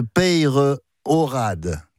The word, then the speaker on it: Peyrorade: Et je vous remercie de bien prononcer le nom de Peyrorade. Comme on dit dans la région. On prononce le E et le Y, et je suis bien placé pour le savoir.